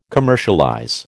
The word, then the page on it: commercialize: American English